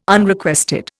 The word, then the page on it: Unrequested: Indian